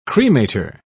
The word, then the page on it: cremator: British English